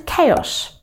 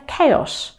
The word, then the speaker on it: chaos: Правильно будет chaos. Да, не chaos или chaos, а именно chaos.